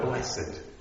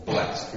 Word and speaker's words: blessed: But the key word is this word blessed, or blessed. We tend to put the extra... whatever it's called, gets just a syllable in there, don't we, often, when we describe it in church, blessed.